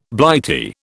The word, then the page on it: Blighty: British English